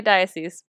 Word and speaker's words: diocese: Did I say that correctly? – That is how you say diocese.